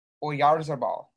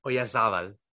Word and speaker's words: Oyarzabal: Mikel Oyarzabal. – Oyarzabal.